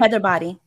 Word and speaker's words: Hyderabadi: Hyderabadi.